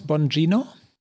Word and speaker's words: Bongino: In dem Ausschnitt fragt, wie spricht man ihn aus? Bongino? – Ja, so habe ich es gehört. So stellt er sich selbst vor.